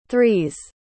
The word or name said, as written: three's